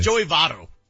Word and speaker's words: Votto: You don't like my pronunciations, Votto. – Apparently. – It's Joey Votto.